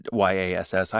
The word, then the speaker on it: Yass: It's Y A S S.